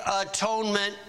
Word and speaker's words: atonement: And it, the four-legged lamb or the four-legged calf, or the bird, it shall be accepted for him to make atonement for him. Now, the word atonement, three syllables, you could pronounce it this way, at one ment.